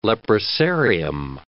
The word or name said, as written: leprosarium